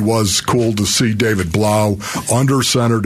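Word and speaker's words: Blough: And doggone it, to David Blau's credit, rhymes with cow, as they said. They released David Blau, rhymes with cow.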